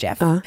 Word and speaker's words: Gef: Den presenterar sig som Jeff, stavat G-E-F. Men det uttalas Jeff.